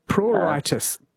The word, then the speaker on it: pruritus: And it is spelled PEU, not No. – PLE. – No, not PE, it's PR, Arthur Roberts, Pruritis.